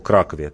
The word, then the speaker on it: Кракове: И э, это помогло ему, когда вот мы говорим о Кракове, ты сейчас правильно говоришь, о спасении Кракова, и это помогло совершить рейд отряду Батяна.